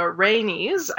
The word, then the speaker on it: Rhaenys: So Varys remembers uh, Rhaenys, I believe is how it's pronounced, Prince Rhaegar's daughter, having uh, a little black kitten that she called Balerion, and who she pretend mm-hmm.